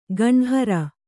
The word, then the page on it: ಗಂಹರ: gamhara